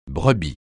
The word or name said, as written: brebis